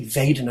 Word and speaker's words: Vedana: The second foundation of mindfulness is a word with a V, Vedana. So if they say Pali with a Burmese accent, they do it with a W. A Burmese accent is Vedana. But the most international standard Pali is V, Vedana.